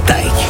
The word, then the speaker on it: Tyke: Moi c'est Tyke, ça se prononce comme Mike mais avec un T.